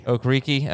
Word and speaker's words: Okereke: Okereke.